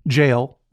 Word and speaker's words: gaol: I got thrown in jail. They spell it G-A-O-L over there.